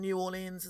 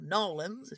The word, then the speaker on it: New Orleans: you know, Nouvelle Orleans, New Orleans and stuff yep. like that, or Narlands, if you want to be wildly inaccurate about the pronunciation.